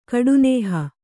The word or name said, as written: ಕಡುನೇಹ